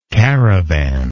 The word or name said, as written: caravanist